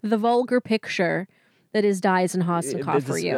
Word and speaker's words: Diazen: I keep calling him Diazian, and we have to keep cutting because I say it wrong. – It looks like Diazian, but it's Diazin. That's how he said it.